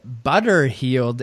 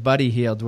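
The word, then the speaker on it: Buddy Hield: Ken had SVP instead of SVG, and Shannon had Butter Healed instead of Buddy Healed, which might be the best mispronunciation of the entire season.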